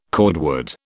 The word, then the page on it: cordwood: British English